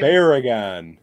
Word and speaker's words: Barugon: Gamera versus, how do you pronounce this thing's name? – Barragan. – Barragan.